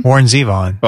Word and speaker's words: Warren Zevon: How do you say, is it Warren Zevon? – Warren Zevon.